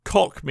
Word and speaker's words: Koch: were, Saber Interactive, Koch Media, I insist on pronouncing it like that, okay.